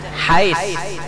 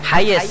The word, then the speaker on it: HiAce: Hiace kan apa bacanya kita? Tulisannya Hiace, Bang. Apa bacanya? Hais. Hayes. Han, lain tulisan, lain bacaan.